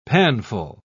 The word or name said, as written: panful